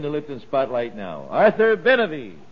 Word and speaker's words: Benavie: Benavie. – Benavie. How do you spell it? – B-E-N-A-V-I-E.